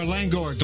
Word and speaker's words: Langohr: Okay, the last name is spelled L-A-N-G-O-H-R, Langor, Glenn Langor.